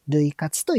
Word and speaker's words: 涙活: えー、突然ですが、お二人は類活されてますか類活というのは、涙する活動と書いて、類活と読みます。で、類活の意味を Google 先生に聞いてみたところ、意識的に泣くことでストレス解消を図る活動のことを類活というみたいです。